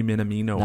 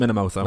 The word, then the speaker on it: Minamino: And the last one was obviously Takumi Minamino How did you say that? – Takumi Minamino. Nice. Not Minamoto. – Not the Minamoto.